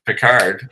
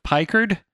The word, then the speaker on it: Picard: You know, it was good because it made, you know, people pronounce it right, Picard. – And, what were they going with? Pikard? Pikard? – Pikard or whatever.